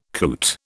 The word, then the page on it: coot: British English